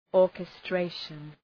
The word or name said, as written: orchestration